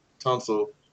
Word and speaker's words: Tunsil: Yeah, maybe Laramie Tunsil. Although Stanley does fit the scheme more, I think Tunsil, if I'm pronouncing that right, might be the better. – You got it right, yeah.